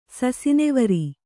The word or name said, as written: ಸಸಿನೆವರಿ